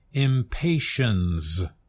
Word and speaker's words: impatiens: It is pronounced impatiens with a Z sound. That's because it comes directly from another language, Latin.